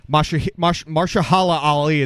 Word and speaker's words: Mahershala Ali: All right, so the best supporting actor, Mahershala Ali is his name from Green Book.